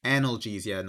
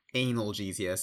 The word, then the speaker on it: analgesia: And yes, it's pronounced analgesia, not analgesia, so let's get that out of the way now.